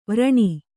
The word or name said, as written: ವಾರಣಿ